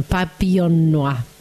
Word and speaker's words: Papillon Noir: Esa es la pronunciación, Papillon Noir.